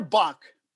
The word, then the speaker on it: Bock: Uh, okay so shiner buck pronounced b-o-c-k is a,